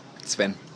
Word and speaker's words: Sven: Sven. – And what are you riding? Because it's actually very, very... – People call me Seven because I can't pronounce my name most of the time.